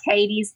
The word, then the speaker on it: Cadiz: It's spelled C-A-D-I-Z. Like, it kind of looks like Cadiz, but locals say it Cadiz.